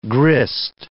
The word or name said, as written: grist